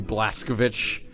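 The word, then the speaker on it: Blazkowicz: Blaskovich. – You said it right. Don't look around. – Yeah, that's how they say it in the game. – Blaskovich.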